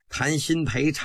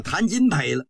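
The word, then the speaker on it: 谭鑫培: 今儿个是谈新陪唱的文昭关，他不认识新字，把这个新字念成金了，叫谈金陪了。光绪微微的一笑，是谈新陪，不是谈金陪。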